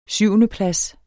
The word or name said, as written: syvendeplads